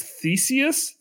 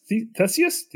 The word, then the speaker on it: Theseus: I don't actually know how to pronounce Theseus. Theseus. Tell us about this. Said it right the first time.